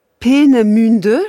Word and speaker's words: Peenemünde: qui s'appelait Peenemünde, je crois que c'est comme ça qu'on prononce. – C'est tout à fait ça.